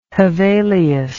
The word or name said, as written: Hevelius